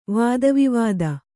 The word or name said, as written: ವಾದವಿವಾದ